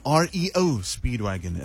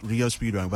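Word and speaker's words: REO Speedwagon: R-E-O Speedwagon, and not Rio Speedwagon. But I promise you, growing up when I saw that, I thought it was Rio. I thought it was Rio Speedwagon, but R-E-O Speedwagon.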